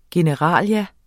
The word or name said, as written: generalia